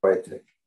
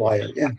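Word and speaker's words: Świątek: Yeah, Swiatek. – Swiatek. – Not Wyatt.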